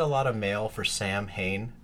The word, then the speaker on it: Samhain: Okay, so look, quick little trivia about Sam Hain. – Not how you say it. – Not how you're supposed to say it. The Gaelic time of year, the darker half of the year, right, is supposed to be Samhain.